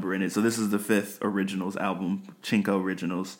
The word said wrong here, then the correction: Cinco: This is unrelated, but I've been doing a lot of Duolingo, learning Italian, and the way Cinco is spelled, you say that as Chinko in Italian. So I'm still in my Italian brain mode. So I said Chinko instead of Cinco.